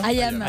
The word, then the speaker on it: IAM: IAM. – IAM, merci.